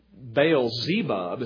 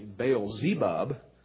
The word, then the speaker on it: Baal-zebub: Baal-zebub is how you pronounce it when it's spelled differently. Baal-zebub.